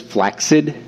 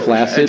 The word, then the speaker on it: flaccid: Do you know the proper pronunciation of that is flaccid, not flaccid? – I did not know that.